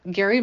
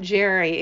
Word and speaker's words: Gerry: Please look up Gary, my partner, my producer, G-E-R-R-Y. It is pronounced Gary, not Jerry. It is confusing, and we're sorry about that. But that's the truth. It is Gary.